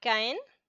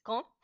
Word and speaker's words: Caen: Vivo en, en español se llama Caen, en francés Caen, y este es... – Se, escribe así, se escribe así, C-A-E-N.